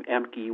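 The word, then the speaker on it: Ehmke: But just my email address is my last name, Emke, which is spelled E-H-M as in Mary, K-E, E-H-M-K-E, the number 5 at AOL.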